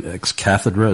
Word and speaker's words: ex cathedra: ex cathedra.